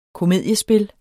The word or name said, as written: KOMEDIESPILLENE